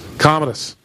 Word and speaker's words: Commodus: I don't know how to say his name. I feel like I want to say Commodus. Don't know too much about the guy. Is it Commodus? – Commodus.